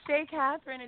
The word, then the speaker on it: Chez Catherine: Now, how do you pronounce it? Chez Catherine or Chez Catherine? – No, it's Chez Catherine. It's Chez Catherine.